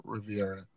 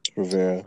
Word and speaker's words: Rivera: Is it Rivera or Riviera? – Rivera. – It's Rivera.